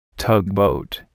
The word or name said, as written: tugboat